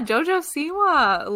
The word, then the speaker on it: JoJo Siwa: Yeah, JoJo Siwa.